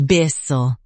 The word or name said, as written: byssal